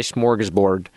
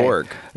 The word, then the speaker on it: smorgasbord: Do you pronounce the second S, smorgasbord? – I pronounce every letter of smorgasbord. Yeah. – Smorgasbord? Yeah. – Smorgasbord. – Smorgasbord. It's a fun one to say. – Borg. – It's board? – Borg. It's a G at the end.